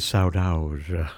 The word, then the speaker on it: saudade: I know saudade is saudade.